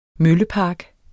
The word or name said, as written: møllepark